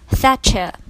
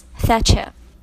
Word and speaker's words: Thatcher: 记住了，撒切尔夫人的正确的说法是 Mrs. Thatcher， OK， Mrs. Thatcher。